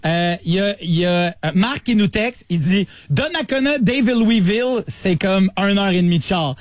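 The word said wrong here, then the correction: Daveluyville: Mais premièrement, ça se prononce Davelouville.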